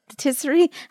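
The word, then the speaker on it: pâtisserie: Patisserie? How do you say it fancy? – Like French. Patisserie.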